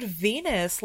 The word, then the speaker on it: Venus: Yes, if you want to save 15% off and help this year podcast, you could use promo code Venus, like the goddess. – Rhymes with penis.